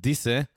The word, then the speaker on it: Dyse: It's a band called from Germany called Disse. It's spelled D-Y-S-E with their song Spinne.